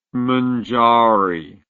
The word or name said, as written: mungaree